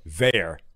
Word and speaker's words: Veilleux: Hang on, he told me how to pronounce his name. Jean-Paul Veil, just spelled V-E-I-L-L-E-U-X.